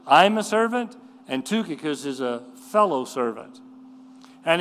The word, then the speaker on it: Tychicus: Now, he starts out by saying in verse 7, all my state that this is pronounced Tychicus, declare unto you, who's a faithful brother and faithful minister, fellow servant to in the Lord.